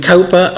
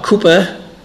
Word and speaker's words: Cowper: There's a long poem by William Cowper, or Cooper, depending on which way you want to pronounce it, who was in the 19th century, I believe, or the 18th century.